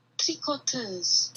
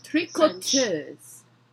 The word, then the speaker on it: tricoteuse: Tricoteuse. – Tricoteuse. – Tricoteuse. – Tricoteuse. – French. – It's an O. Tricoteuse.